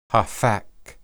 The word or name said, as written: haphak